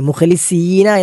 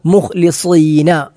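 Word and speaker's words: മുഖ്ലിസീന (مخلصين): സീൻ എന്ന അക്ഷരം നമുക്കറിയാം വായ നിറച്ചല്ല, പല്ലുകൾ കടിച്ചു പിടിച്ച് ഉച്ചരിക്കേണ്ട അക്ഷരമാണ് സീൻ. മുഖ്ലിസീന എന്ന് നമ്മൾ ഉച്ചരിച്ചാൽ അത് തെറ്റാകും. മുഖ്ലിസീന, മുഖ്ലിസീന എന്ന് വായ നിറച്ചുച്ചിരിക്കേണ്ട സ്വാദ് എന്ന അക്ഷരമാണ് മുഖ്ലിസീന.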